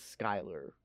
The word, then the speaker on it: Schuyler: It's S C H U Y L E R, but it's Skylar. I paid attention this time. – Okay. – At Philip Skylar's ball.